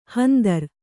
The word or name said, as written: ಹಂದರ